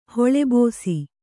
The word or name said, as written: ಹೊಳೆಬೋಸಿ